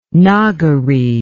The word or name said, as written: Nagari